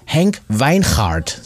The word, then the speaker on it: Henk Wijngaard: Da kommt er her aus Staatskanal und hier ist Henk Wijngaard. So wird er nämlich richtig ausgesprochen.